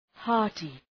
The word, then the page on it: hearty: ‘hɑ:rtı